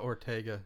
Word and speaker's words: Ortega: Jenna Ortega.